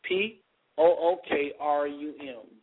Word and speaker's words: Pookrum: I mispronounced her name the other day. It's Jewel, J-E-W-E-L, Jewel Pokrum, P O-O-K-R-U-M, Jewel Pookrum.